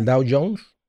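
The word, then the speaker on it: Dow Jones: Pero en el, eh, en el, eh, en inversión, pero pues no, a veces está mal, a veces Down, down Jones. ¿Cómo se dice? ¿El Dow Jones? – Ajá.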